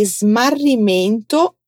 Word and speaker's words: smarrimento: Tem a letra S muda, então atenção, vem comigo. Mento. Smarrimento. Vem de smarrire, que é perder. Agora fala comigo. Denuncia di smarrimento.